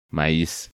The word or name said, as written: Maiz